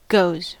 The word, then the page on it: goes: American English; /ˈɡoʊz/